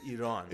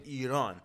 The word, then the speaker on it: Iran: right now, there's a country named Iran. – Iran. – That's the right way to say Iran. Iran. All right? It's Iran for the white people.